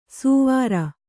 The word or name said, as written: ಸುವಾರ